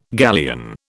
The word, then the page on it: galleon: British English